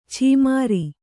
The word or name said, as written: ಛೀಮಾರಿ